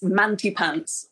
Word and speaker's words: Mantepants: It's Manty Pants, which is spelled like this. M-A-N-T-E-P-A-N-T-S.